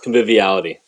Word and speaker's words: conviviality: We love the casual atmosphere and like you know I'm going to say it wrong, convivial. – Conviviality. – Yes. there we go.